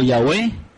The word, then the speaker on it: Yahweh: Nosotros nos limitamos a la pronunciación del nombre como Yahweh, que es la pronunciación más antigua y la que se considera más acertada acerca del nombre por eruditos, tanto judíos como algunos cristianos.